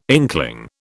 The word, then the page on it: inkling: British English